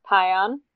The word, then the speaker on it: Pion: My last name is pronounced Pion, like pie on, not peon.